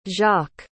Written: jacque

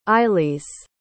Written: ilys

